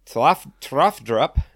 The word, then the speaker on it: Tafdrup: And then it's Tafdrup. – That's it. – T-A-F-D-R-U-P. So Tafdrup, whatever.